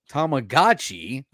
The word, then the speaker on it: Tamagotchi: Tamagotchi, a counterpart to the Tamagotchi, was released just a year later, known as the Digital Monster.